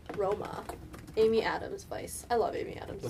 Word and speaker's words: Weisz: Rachel Weisz. I hope I said that right. – Rachel Weisz. – Yeah, her name is Vice.